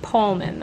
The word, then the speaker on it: Poehlmann: P-O-E-H-L-M-A-N-N. Pronounced Pullman, though.